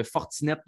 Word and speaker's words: Fortinet: on commençait ça parlant de vin du côté de Napa en Californie avec le, Fortinet Championship pour le prononcer à, la québécoise.